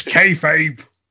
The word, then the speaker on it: kayfabe: Keith Abe's dead. Let's just say, all right, well, Keith Abe's dead. Keith Abe's dead. – It's kayfabe. – Whatever. It's so dead I can't even say it right.